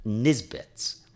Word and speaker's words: Nisbet's: It's called Nisbet's. – So how do you spell that? – N-I-S, Nis, and then Bets, B-E-T-S.